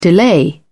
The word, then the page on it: delay: British English